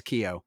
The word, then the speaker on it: Keough: Ki- Keough. Keough. That's how you pronounce her name. I just oh, saw it. okay. Cool. – Keough. Sorry, Riley. It's Keough.